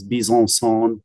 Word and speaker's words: Bezanson: And so it's a French last name. There's actually a little community in France named Bezanson, France, or if you want to actually pronounce it, it's Bezanson.